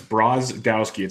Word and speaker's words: Brodowski: It's Brodowski. It's like it's spelled.